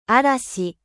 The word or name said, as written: orage